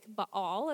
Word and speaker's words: Baal: Now, he married Jezebel, who is someone who worshiped Baal, or you might hear it like Baal, is how the Bible Project pronounces it.